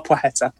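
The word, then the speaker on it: Płacheta: It's Szemysław. Plaheta or Poheta. Oh, so Poheta and Szemysław. It's not easy. I'm going to take a lot of pronunciations. Norfolk accents will not cope with that very well. It does not sound good in Norfolk accent, but yeah, Szemysław Poheta.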